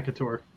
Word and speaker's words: Akator: Did I say that wrong? – Yeah. Akator.